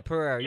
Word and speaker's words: Pereira: I might be pronouncing Pereira wrong. Yeah, Pereira.